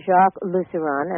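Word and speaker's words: Lusseyran: by Jacques Lusseron, and correct me if I mispronounce it. – No, that's right.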